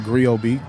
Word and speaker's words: Griot: Uh, the Griot, that's G R I O T. The T is silent like D in Django.